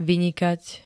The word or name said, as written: vynikať